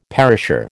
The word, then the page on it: perisher: American English